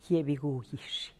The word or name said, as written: giebigugix